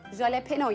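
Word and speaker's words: jalapeño: Jalapeno yazılıyor ama jalapeno okunuyor.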